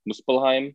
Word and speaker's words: Muspelheim: Odin sends the three to Muspelheim. Am I pronouncing that right, Clark? Muspelheim? – I believe so, yeah.